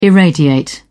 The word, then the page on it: irradiate: British English